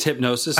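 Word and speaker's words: Hipgnosis: I think it's Hypnosis. – I think the G is silent. Hypnosis Songs Fund. There's a weird G in there.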